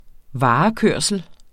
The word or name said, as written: varekørsel